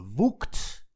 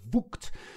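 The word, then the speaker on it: Vogt: Je hoorde helemaal aan het begin, eh, voor de tune, de stem van Eskil Woekt. Zo moet je het uitspreken. Ik heb hm. hem nog specifiek gevraagd. Woekt.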